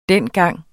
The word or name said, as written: dengang